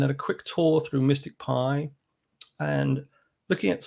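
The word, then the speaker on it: MSTICPy: So it's pronounced Mystic Pi, even though it's spelt rather oddly.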